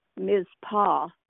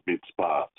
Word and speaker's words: Mizpah: I mispronounced it when I was reading it just now. I pronounced it mitzvah.